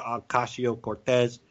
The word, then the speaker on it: Ocasio-Cortez: Go to Google Images and put in Alexandria Occasional Cortex, or actually, how do you say her name? AOC, Alexandria Ocasio-Cortez. And then put in Heba, Abedin.